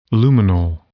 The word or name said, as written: lumenal